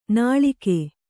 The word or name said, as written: ನಾಳಿಗೆ